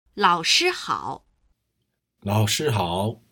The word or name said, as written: laoshi hao